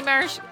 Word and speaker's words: maraschino: Is it supposed to be maraschino? Am I saying it right? – Yes.